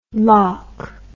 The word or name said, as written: lawk